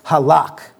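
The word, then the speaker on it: halak (הָלַךְ): The common word for walk in the Hebrew is a term pronounced halak.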